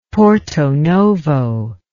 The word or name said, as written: Porto Novo